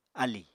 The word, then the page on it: álì: Apatani